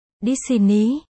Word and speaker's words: Disney: Gốc ở đây là Dinisi, Dinisi, Disney bên đó phát âm là Disney, không biết có liên quan gì đến nhau không nữa.